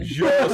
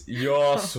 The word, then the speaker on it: Joss: Joss, I think it's a soft J. It's Joss Whedon.